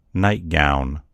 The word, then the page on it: nightgown: American English